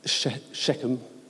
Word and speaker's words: Shechem: Shechem, that is how you pronounce it, just to, in case anyone is in disagreement, is the capital.